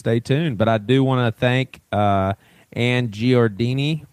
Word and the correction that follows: Giardina: how do you say her last name, man? – She's, I think I would say Giardina. – Giardina, yeah, that's right. That's an A.